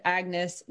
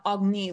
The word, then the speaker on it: Agnis: She requires that you pronounce it Agnes, not Agni, like her pretentious royal family does.